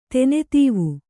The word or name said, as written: ತೆನೆತೀವು